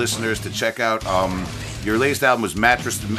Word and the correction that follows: Matricide: Am I pronouncing that right? – Matricide. Ma- matricide. Matrix. It's the Long Island tongue, man.